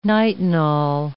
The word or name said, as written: nitinol